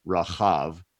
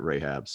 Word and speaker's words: Rahab: So it's really Rahav, which is sometimes spelled R-A-C-H-A-B. But we're used to pronouncing it Rahab, so we're just going to stick with that pronunciation.